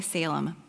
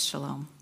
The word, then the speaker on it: Shalom: Now, our Bibles say Salem, but it's Shalom. It is spelled the same.